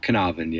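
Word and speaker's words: Caernarfon: Carnarvon Castle is, you put some effort into the Welsh pronunciation, but Carnarvon, yeah.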